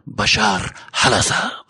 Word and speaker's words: Bashar al-Assad: It's actually pronounced Bashar al-Assad.